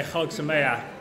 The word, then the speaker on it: Chag Sameach: Generally, you just say Chag Sameach at any holiday, but.